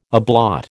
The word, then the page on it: ablaut: American English